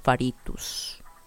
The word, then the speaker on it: Faritos: So instead of Laura Faria, I'm gonna be Laura Faritos. And that's me, Laura Faritos.